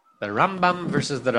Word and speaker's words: Rambam: So he would say that the way you distinguish it is that which syllable you stress, it's the Rambam and the Ramban. So this is the Ramban.